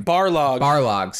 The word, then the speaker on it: Barlog: Barlog, sorry.